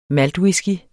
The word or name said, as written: maltwhisky